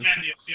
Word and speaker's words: Ozymandias: Ozymandias, yeah.